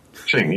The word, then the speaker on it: Xing: Xing, yeah. – It's spelled with an X. – Somewhere between a J and a Z.